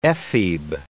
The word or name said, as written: ephebe